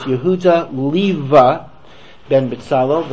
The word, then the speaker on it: Leva: Leva is an old German term for Leib. It's not in use anymore, because Leib is, of course, the Yiddish for lion, Yehuda Leib, right? So it was Yehuda Leva was the older term. It's not a common pronunciation anymore.